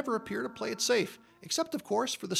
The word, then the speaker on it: Saiph: But poor SAFE, which is spelled S-A-I-P-H.